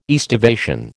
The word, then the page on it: aestivation: American English